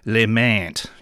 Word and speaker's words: Lemant: just a terrible pronunciation of their surname. It's not meant to be said like, as if it's said by some ridiculous stereotype French baker. It is Marcel Lemant, you know, good old Aussie pronunciation.